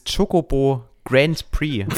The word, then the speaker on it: Chocobo Grand Prix: Ähm, wo wir gerade über kleinere Titel reden, ähm, dieses Chocobo Grand Prix.